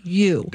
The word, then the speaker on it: ewe: No, it's a ewe. I think it's E-U-E, and it's pronounced ewe.